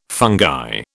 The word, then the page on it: fungi: British English